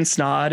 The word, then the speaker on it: Snod: So my name is Ryan Snod. It rhymes with odd. A lot of people have a hard time pronouncing it, so I usually preface it by saying that.